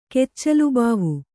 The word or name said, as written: ಕೆಚ್ಚಲುಬಾವು